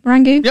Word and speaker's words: merengue: Upstairs is the merengue. I can't remember how do you pronounce it? Merengue? – Yep. Merengue.